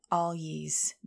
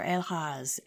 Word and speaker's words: Algiz: We're looking at Algiz this week, or Algiz, as some people pronounce it.